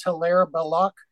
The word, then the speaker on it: Hilaire Belloc: In English, it is Hilaire Belloc. In French, it's Hilaire Belloch.